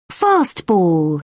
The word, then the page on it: fastball: British English